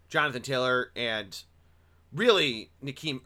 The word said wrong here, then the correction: Nyheim: Nyheim Himes.